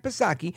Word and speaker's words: Psaki: But when asked about it, the White House press secretary, Karine Jean-Pierre, now she's no circle back Psaki, silent P, of course,